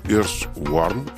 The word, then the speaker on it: Earthworm: Vous êtes directeur de la fondation Earthworm. Est-ce que je le dis bien? Parce que mon anglais n'est pas... – C'est parfait. Earthworm, ça veut dire ver de terre en anglais. Donc, c'est parfait.